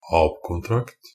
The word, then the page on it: ab-kontrakt: Norwegian Bokmål